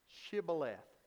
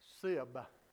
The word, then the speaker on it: Shibboleth: If he said no, then they would say to him, say now, Shibboleth. Now look at that. Shibboleth. But he said, Sibboleth. Sibboleth. For he could not pronounce it correctly.